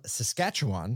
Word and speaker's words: Saskatchewan: Today's guest is Erin Richmond, and she's from Saskatchewan.